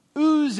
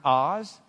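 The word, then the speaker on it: Uz (עז): Uz is the Hebrew word for strength. It also can be pronounced Oz.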